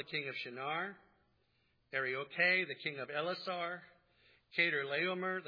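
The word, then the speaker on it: Arioch: Arioche is better pronounced Ari-Aku.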